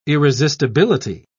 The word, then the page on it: irresistibility: British English